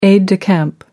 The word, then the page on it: aide de camp: American English